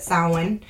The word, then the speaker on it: Samhain: But for the 9th one, I could not leave out my history, which is Samhain, or as others pronounce it, Samhain.